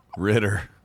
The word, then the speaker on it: Ritter: Rhymes with knitter. – Ritter.